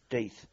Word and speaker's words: Death: there's a driver whose name actually is pronounced Deeth, but spelt Death, and we joked and said, whatever you do, don't go on that Hastings.